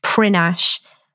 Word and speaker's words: Prinknash: It's actually spelt Prinknash, but it's pronounced Prinash.